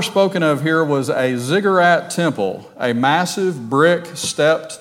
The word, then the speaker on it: ziggurat: Uh, but it said ziggurat. So I thought of, well, not cigarette, but ziggurat.